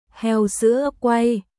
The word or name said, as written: Heo sữa quay